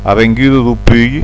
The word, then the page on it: Pui: (lo) Pui; Prononcer "(lou) Puÿ, Putch"; puï; graphie alibertine